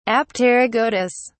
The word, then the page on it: apterygotous: British English